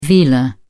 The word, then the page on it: velar: British English